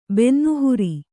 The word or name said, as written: ಬೆನ್ನುಹುರಿ